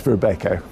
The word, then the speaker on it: Robeco: Depends if you're Dutch. It's Robeco.